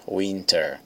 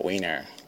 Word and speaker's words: winter: Observe que quando ela pronuncia a palavra inverno, winter, ela pronuncia o T, winter. Mas às vezes os americanos falando rápido, eles pronunciam esse, eles atropelam esse T, aí fica winner, como o caso do Andy, ele fala, I like winter too, I like winter too, eu gosto de inverno também.